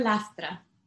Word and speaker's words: Lastra: Dr. Lastra.